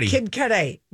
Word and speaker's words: Kid Cudi: Kid Cudi, is that how I say his name? – Kid Cudi.